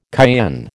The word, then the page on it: cayenne: American English